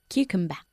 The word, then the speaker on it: cucumber: Cucumber. – Ok, acabando en un sonido de A. Primero, hay que tener cuidado con Q, la primera sílaba, Q, uh-huh. – Y luego con la última, A, cucumber.